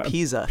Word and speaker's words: Pisa: So is it Pisa or Pisa? – Pisa, I always pronounce it.